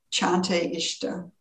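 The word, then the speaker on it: Čante Išta: I found that in the Lakota language, there is the phrase, I think pronounced Chante Ishta. It's spelled C A N T E I S T A.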